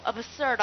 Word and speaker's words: absurd: 那么你看一下，是不是死了变蝴蝶飞走了？你看爱不死的，是不是很荒谬？absurd 的，爱不死的，好，写它的发音，好，absurd 的，爱不死的。